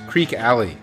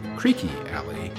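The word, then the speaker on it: Creeque Alley: We heard the Mamas and the Papas do theirs, Creek Alley, from 1967. I found out while preparing this show that that's more correctly pronounced Creaky Alley.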